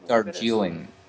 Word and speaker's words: Darjeeling: I don't know if I'm pronouncing that right. – Dejarlene Limited. I'm pretty sure that the girl that's in that movie, I have to... How do you... Okay, so we would look for... How about Darjeeling Unlimited? – How do you say it? – Darjeeling Unlimited? – Darjeeling.